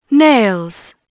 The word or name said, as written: nails